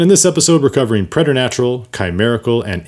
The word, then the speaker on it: preternatural: It is spelled P-R-E-T-E-R-N-A-T-U-R-A-L, preternatural. Some people like to pronounce it preternatural, but I think that kind of sounds pretentious.